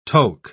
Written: toque

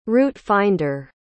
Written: route-finder

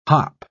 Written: hop

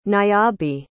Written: niabi

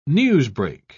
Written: newsbreak

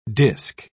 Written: disk

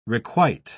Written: requite

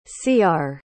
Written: ciar

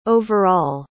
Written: overall

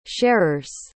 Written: sharers

